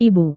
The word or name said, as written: ibu